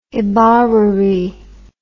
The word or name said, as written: ibarruri